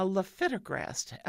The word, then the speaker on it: lifitegrast: And I checked several sources, and it is pronounced Xiidra, X-I-I-D-R-A. That's the name brand. The generic is a lifitegrast, L-I-F-I-T-E-G-R-A-S-T, lifitegrast.